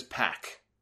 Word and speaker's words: Pac: His name is Pac. That's how they pronounce it. That's how I pronounce it. I had a few people, oh, it's Pac, Solomonster. No, it's not. It's Pac.